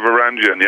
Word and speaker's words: Varangian: Yeah, Varangian, yeah, that's right. – Yep, what can you tell us about one? That's the other new one that you've released as a single. – Varangian